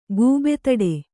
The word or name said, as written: ಗೂಬೆತಡೆ